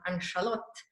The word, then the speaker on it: Ann-Charlotte: And so the nickname for Anne Charlotte, which in Swedish is pronounced Ann-Charlotte, is...